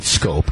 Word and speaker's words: Schoop: Um, it, the, the, name is spelled S C H O O P, but they pronounce it Scope.